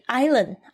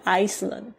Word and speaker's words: island: 他说呢他现在住在哪里 ?On the island。 Island 这个字呢注意一下他的拼法是 I-S-L-A-N-D。有很多同学呢看到这 island 会念成 Iceland。 Iceland 是冰岛。 Island 才是岛的意思。大家注意一下那个 S 是没有发音的。好这边就是念 island。